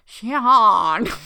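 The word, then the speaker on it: Seán: Okay, and I don't know how to pronounce this because there's a weird accent, but I would say instinctively Sean because it's spelled S-E-A-N, but there's an accent over the A. So I don't know if it's like Sean. – No, that's just the Gaelic way you write it. It's Sean.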